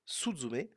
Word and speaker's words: Suzume: Eh bien ce film, ça s'appelle Sutsume, Suzume, S-U-Z-U-M-E, e,